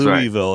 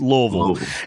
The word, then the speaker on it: Louisville: You have to say it right. You can't say That's Louisville. Right. It's Louisville.